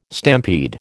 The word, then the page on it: stampede: American English